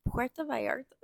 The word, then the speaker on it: Puerto Vallarta: Puerto Vallarta.